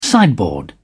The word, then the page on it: sideboard: British English